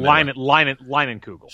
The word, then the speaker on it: Leinenkugel: It's kind of like, in the past, I've been a big fan of the summer shandy, especially the is it Leinenkugel? Is that how you pronounce it? I'm not from the Leinenkugel. – Sure.